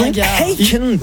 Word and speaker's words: Akon: Oui, il faut le prononcer aussi à la française, je veux dire. Oui, vit dans un pays de droit et de valeur. Et Akon que ça se prononce, et c'est ça, Akon.